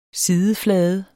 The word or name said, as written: sideflade